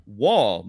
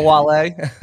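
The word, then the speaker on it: Wahle: It is Mike Wall. Mike, am I pronouncing your last name correctly? – Yeah, you are, and I appreciate you announcing the last name correctly. – It's not Wale.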